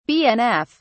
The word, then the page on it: bnf: British English